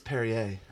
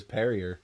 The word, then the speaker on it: Perrier: That is Perrier. – Perrier. I always just thought it was Perrier.